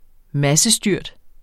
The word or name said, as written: massestyrt